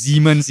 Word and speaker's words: Siemens: It is spelled Siemens, but it's pronounced Siemens in Germany.